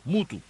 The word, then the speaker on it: Muthu: la MGM Mutu, así se escribe, M-U-T-H-U, Mutu,